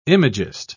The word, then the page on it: imagist: British English